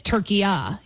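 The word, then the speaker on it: Türkiye: Okay, so it's not what we would call, you and I are making it a hard A, turkey A, and it's turkey-a, saying. So it's a soft A.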